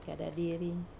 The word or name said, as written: kadadiri